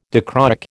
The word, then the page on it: dichroic: American English